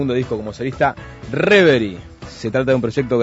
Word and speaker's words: Reverie: En realidad, en francés es Reverie.